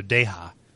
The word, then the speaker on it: Deyja: I kind of want to give it a, a, a, soft J. So Deyja.